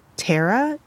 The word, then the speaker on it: Taira: but I believe it's pronounced Tara, T-A-I-R-A.